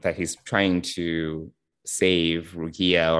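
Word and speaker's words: Rukiya: That's how you say her name? Is that right? – Right, Rukia, yeah.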